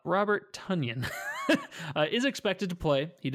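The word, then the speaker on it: Tonyan: Yeah, Tunyon. That's how you pronounce it. – Tunyon, like onion. – Tunyon. Oh, I like it like Paul Bunyan.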